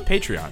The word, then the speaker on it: Patreon: If you like the show and want to help us make it even better, consider supporting us on Patreon. I have so much trouble with the word Patreon. Is it? It's Patreon? – It's Patreon.